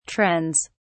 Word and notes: trends: British English

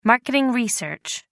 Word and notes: marketing research: American English

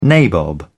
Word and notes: nabob: British English

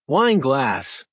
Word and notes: wine glass: American English

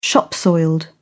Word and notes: shop soiled: British English

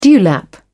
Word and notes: dewlap: British English

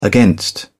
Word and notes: against: British English

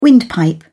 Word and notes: windpipe: British English

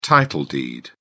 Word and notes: title deed: British English

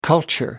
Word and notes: culture: American English

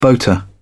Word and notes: boater: British English

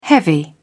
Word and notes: heavy: British English

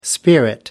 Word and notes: spirit: American English

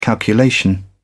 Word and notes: calculation: British English

